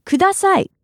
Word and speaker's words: ください: ください. Broken down by syllable, it's ください. All together now? ください.